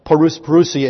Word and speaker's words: parousia: The parousia is the English pronunciation of the Greek word for the coming of Jesus. It's parousia in Greek.